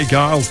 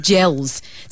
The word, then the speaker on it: Geils: That's the Jay Giles Band with Freeze Frame – The Jay Giles Band, because it's spelt G-E-I-L-S, so it's almost like gels.